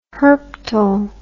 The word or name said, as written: herptile